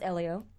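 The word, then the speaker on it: Hélio: That guy spinning out going into the pits, or Helio winning Dance with the Stars? Who got oh, more grief? – Elio, huh? and by the way, it's Elio. – Elio, sorry, Silent H.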